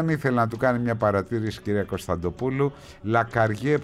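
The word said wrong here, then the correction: Lacarrière: προφέρεται λέει με ρο στο τέλος, λέει ο Κώστας, είναι Λακαριέρ, μάλιστα, αφού σας είπα τα γαλλικά μου, σε ευχαριστώ πολύ Κώστα.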